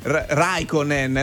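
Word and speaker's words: Räikkönen: Raikkonen, l'accento è sulla prima.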